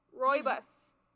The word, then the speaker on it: Rooibos: And it is pumpkin spice Robos, I think is how you pronounce it. Hold on. Where is it? Rooibos. Rooibos. R-O-O-I-B-O-S. Pumpkin spice Rooibos.